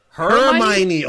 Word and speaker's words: Hermione: Because I've heard Harmony. Am I saying that wrong? – That's wild. – Harmony. – You messing it up is making me forget how to pronounce it. Actually, Harmony. – Hermione. – What is it? – Hermione. I was like, that's not how you say it.